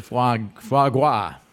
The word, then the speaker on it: foie gras: It's like the foie gras.